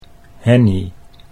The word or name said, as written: Henny